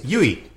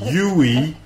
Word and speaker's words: Yui: Sorry, it's Yui, not Yui.